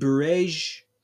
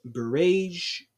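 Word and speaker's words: Bureij: Because there's been heavy bombings in Bereij, I think that's how you say it, Bereij, the refugee camp in central Gaza, and there's been bombings, more bombings in Khan Yunis and Rafah.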